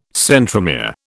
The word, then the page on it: centromere: British English